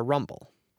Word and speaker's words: RMBL: She's a stream ecologist at the Rocky Mountain Biological Laboratory in Gothic, pronounced RMBL or Rumble.